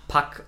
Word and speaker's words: Puck: Äh, es wird Pack ausgesprochen, aber mit U geschrieben, weil ähm, das erinnert mich so ein bisschen an Mitsommernachtstraum. Da gibt es auch eine Figur, der Puck, und wird im Englischen auch Pack ausgesprochen.